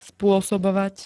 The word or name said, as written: sposobovat